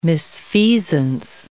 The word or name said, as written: misfeasance